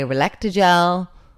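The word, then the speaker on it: Relactagel: So we are sponsored this week by Relectagel. Am I saying it right? – You are.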